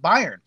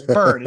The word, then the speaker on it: Byrne: Because again, we were really young and he was just, that's what it looked like to the page, said B-Y-R-N-E. If you never heard that pronounced before, you're going to pronounce it Byron. And so, but I had already known, but I think by that point that, because I knew that it was pronounced Burn, and I would always have to correct him. It's like, it's Burn. It's like, but it's spelled Byron.